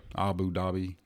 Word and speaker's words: Abu Dhabi: I think Brooks is playing at Abu Dhabi. – Yeah. – Abu Dhabi.